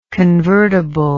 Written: convertibly

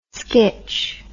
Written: skitch

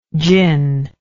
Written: djin

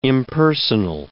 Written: impersonal

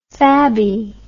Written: fabby

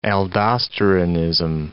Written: aldosteronism

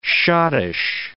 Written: schottische

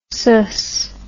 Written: sus

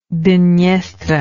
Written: Dnestr

